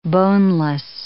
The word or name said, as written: boneless